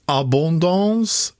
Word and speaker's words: Abondance: Än så länge så gäller ostarna Abondant, med reservation för det franska uttalet, Abondance, Beaufort och Comté. – Lät jättebra.